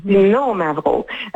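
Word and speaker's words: Xinomavro: on dit qu'il y a un accent sur le no, donc Cino Mavro.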